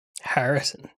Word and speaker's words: Harrison: Heresy. – Harrison. It's pronounced Harrison. – Oh, yeah, that's right. It's not heresy.